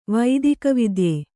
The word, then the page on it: ವೈದಿಕವಿದ್ಯೆ: Kannada